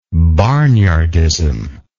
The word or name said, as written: barnyardism